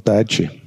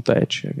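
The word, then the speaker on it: Dyche: Sean Deitche. – Sean Deitche, genau.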